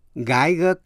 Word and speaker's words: Geiger: Ngoại lệ là chữ G-E-I phát âm là guy. Geiger counter. Geiger counter. G-E-I-G-E-R. Counter. C-O-U-N-T-E-R.